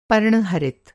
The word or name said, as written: पर्णहरित